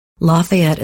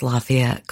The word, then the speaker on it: Lafayette: Lafayette is pronounced differently in many southern states. I watched a documentary on Louisiana's Lafayette called Hub City. Even locals say it differently. Some say Lafayette, but many say Lafayette. Forgive me if I slip and say it both ways.